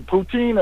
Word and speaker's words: poutine: But uh, yeah, I learned about uh, some poutine.